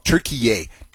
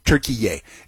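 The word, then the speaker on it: Türkiye: And they mispronounce Turkey. They call it Turkiye. Turkiye. It's just Turkey.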